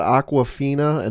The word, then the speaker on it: Awkwafina: You've got Awkwafina, and no, I'm not pronouncing that wrong. If you look up her name on the IMDb page for Shang-Chi and the Legend of the Ten Rings, that's how it reads.